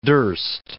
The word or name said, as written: durst